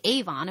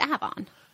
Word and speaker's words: Avon: So like we have a town called Avon and some people would say Avon and we're like, no, it's Avon.